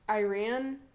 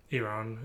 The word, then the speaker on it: Iran: Um, there's I don't know there's other stuff happening in the media too, because I think like when you Iran is like the wrong pronunciation. – Iran is the correct one. Yeah, but we say Iran because. – But it's I and Ran.